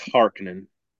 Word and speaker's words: Harkonnen: If you remember the original movie, they pronounced it Harkonnen. And I got into several arguments about that. Was one initially my biggest complaint about the new movies is they're saying Harkonnen. And I'm like, it's Harkonnen. But when I looked it up in the Dune Encyclopedia, I was wrong. – It's okay. I forgive you. – So it's Harkonnen.